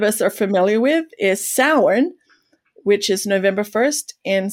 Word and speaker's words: Samhain: And Samhain, by the way, it looks like it's pronounced Samhain. So if you see it in writing, you're going to read it as Samhain, but it's a Gaelic word, so it doesn't sound much like it looks. But that's how you say it, is Samhain.